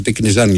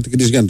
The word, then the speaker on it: Τικνιζιάν: Ο Τικνιζιάν με τον Ρόκα, γιατί δεν προφέρεται, λέει, είναι με το αλφα, Τικνιζιάν λοιπόν να το λέμε. – Όπως ναι, εντάξει ρε παιδί μου, και εγώ δεν το ξέρω, Τικνιζιάν το λέμε.